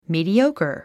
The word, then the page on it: mediocre: mìːdióukər; ミーディオゥカァ